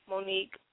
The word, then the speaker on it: Monique: You can find me on Facebook, Cheyenne Monique, Cheyenne, S-H-I-A-N-N-E, Monique, M-O-N-I-Q-U-E.